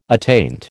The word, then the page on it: attaint: American English